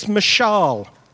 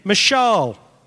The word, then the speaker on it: mashal: The Hebrew word is mashal. So say it after me. Mashal. Try again. You're not quite got the Hebrew accent, so try it again. Mashal, basically, like a long A over there.